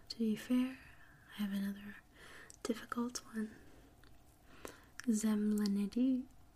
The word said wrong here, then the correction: Zemblanity: I'm saying it wrong. It's spelled Zemblanity. Zemblanity.